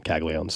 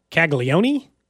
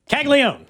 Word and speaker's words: Caglione: What do they think it is, like Caglione? – I think so, but it's definitely Caglione? – Not. It's definitely not that. – Caglione. That's his name.